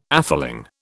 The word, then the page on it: atheling: British English